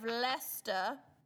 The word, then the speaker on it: Leicester: The villages of Narborough and Enderby, outside of Leicester, which rhymes with Uncle Fester and not...